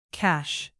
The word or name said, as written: cache